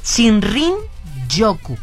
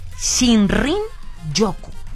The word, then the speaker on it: Shinrin Yoku: En uno de sus posteos escribió Shinrin Yoku. Shinrin Yoku.